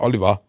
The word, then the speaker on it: Oliver: Sagen wir, sagen wir, äh, sagen wir sehr pädagogisch bei Twitter reinschrieb, aber der sagte, ey, der wird nicht französisch aus, ausgesprochen, was der Vorname ja nahelegt. Der Vorname Olivier, ist nochmal wie? – Olivier, mhm. Olivier, ja, egal, aber Oliver. Ja, Oliver.